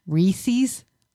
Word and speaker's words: Reese's: I hate it when people mispronounce Reese's and Reese's peanut butter cup. – What do they say? – Reese's. – Oh, Reese's. Yeah, I have heard that. I say Reese's, but yeah. Oh, you can't be mad at anybody pronouncing anything wrong. – I don't know how they do it, though. It's E-E. – Yeah. Yeah. – Reese's. Not Reese's.